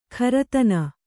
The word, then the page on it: ಖರತನ: kharatana